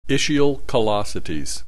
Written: ischial callosities

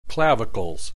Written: clavicles

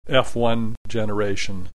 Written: F1 generation

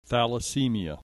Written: thalassemia

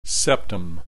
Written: septum

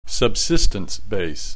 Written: subsistence base

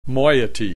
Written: moiety

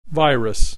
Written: virus

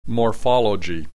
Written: morphology

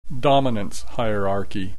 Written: dominance hierarchy

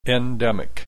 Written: endimic

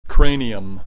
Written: cranium

